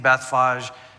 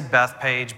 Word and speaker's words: Bethphage: I'm saying Bethphage. Some people say Bethpage, but there's an H in there. I'm going to pronounce it.